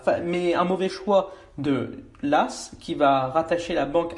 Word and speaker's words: Law: La France est en retard notamment en raison de l'échec de John Law, euh, prononcé Las, d'ailleurs. Ça s'écrit Law L-A-W et ça se dit John Las.